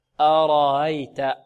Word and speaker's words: أرأيت: الهمزة المسهلة كذلك مرققة، هذه لا نقول أرأيت ها ها، لا أرأيت، أرأيت، أيت.